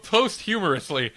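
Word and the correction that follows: posthumously: Post-humously? – Thank you.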